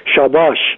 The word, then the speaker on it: شاباش: اضافه کنم که این واژه شادباش در زبان مالی هم به همین معنی رفته، البته با تلفظ شاباش.